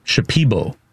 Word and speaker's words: Shipibo: So the tribe I was talking about earlier in the Amazon in Peru is, it's Shipibo is the name. Because I know I mispronounced it earlier, so I wanted to.